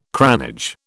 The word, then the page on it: cranage: British English